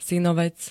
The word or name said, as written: synovec